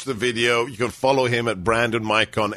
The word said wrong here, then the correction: Michon: M-I-C-H-O-N. I hope I'm pronouncing that correctly. Brandon Mikon, M-I-C-H-O-N. – It's Michon, but it's okay. It's a French name. – Michon. We will go full François on you. Brandon Michon, M-I-C-H-O-N.